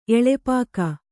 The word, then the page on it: ಎಳೆಪಾಕ: eḷepāka; kannada